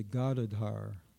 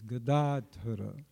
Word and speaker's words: Gadadhara: Most devotees say Gadadhar. Gadadhar. With the accent on the first syllable and the third. Actually, it's Gadadhara. It's only the second syllable that has the long A. Gadadhara. Dhara means holder. So in our Panchatattva mantra, it's not Gadadhar, it's Gadadhara.